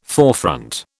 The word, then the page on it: forefront: British English